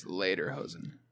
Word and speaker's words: Lederhosen: How do you say that word? – Later? I think it's Lederhosen.